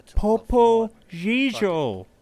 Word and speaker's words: Popocatépetl: I believe it's pronounced Popocatépetl. – Popocatépetl.